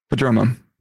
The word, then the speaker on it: Prodromou: How do you say Prodromou. It? – Prodromou.